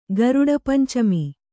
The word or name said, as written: ಗರುಡಪಂಚಮಿ